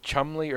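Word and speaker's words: Cholmondeley: I don't remember if it's Scottish or Irish or what, but there's one that's spelled Chalmondly, and it's pronounced Chumley or something else even like really, really simple.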